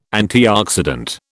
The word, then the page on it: antioxidant: British English